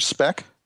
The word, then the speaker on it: Speck: Like Richard Speck? – Yeah, except with a sh instead of a sp. – Okay. So Speck. – It's spelled the same way, though.